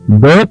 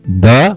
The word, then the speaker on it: the: ದ ಪದವನ್ನ THE ಅನ್ನುವ ಪದ ಏನಿದೆಯಲ್ಲ, ಇದನ್ನ ದ ಅಥವಾ ದಿ ಅಂತ ಹೇಳಿ ಉಚ್ಚರಿಸಬಹುದು. ದ ಮತ್ತು ದಿ, ಮತ್ತೆ ಕೇಳಿಸ್ಕೊಳ್ಳಿ, THE ಪದವನ್ನ ದ ಅಥವಾ ದಿ ಅಂತ ಹೇಳಿ ಉಚ್ಚರಿಸಬಹುದು. ಎರಡು ಉಚ್ಚಾರಣೆಗಳಿಗೆ ಅವಕಾಶ ಇದೆ.